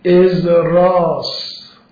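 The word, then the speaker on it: ازرا (עזרא): این عزیر در عبری تلفظش ازراس، ازرا مثل خود موسی که عبری تلفظش چیست؟ موشی. خب به جا سین شین میگذاره.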